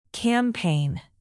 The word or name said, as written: campaign